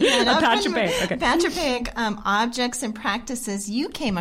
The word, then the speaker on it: apotropaic: And what are some examples of the apotropaic, and am I saying that right? Say it again. – Apotropaic? – Apotropaic. – There you go.